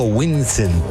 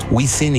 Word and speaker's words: Wisin: Es, yo digo Winsen, pero es Wisin y Yandel.